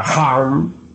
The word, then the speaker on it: HAM: HAM escribe con las letras H-A-M. SAG se escribe con las letras S-A-H. La H suena siempre como J.